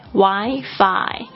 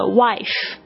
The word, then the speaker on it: Wi-Fi: 好，再来就是讲一下这个无线网络，刚才大家已经听到这个是 Wi-Fi，它是 W-I，有时候中间会加上一横 F-I Wi-Fi，请大家注意发音，因为我之前有学生他就发成这个 wife，就闹了一个笑话 wife 是老婆的意思，他就是这样问人家，他就问那个服务员，他就说 Do you have free wife?